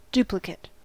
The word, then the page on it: duplicate: American English